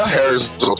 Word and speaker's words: Herzl: Herzl. É assim que pronuncia? – Eu pronunciaria Herzl também.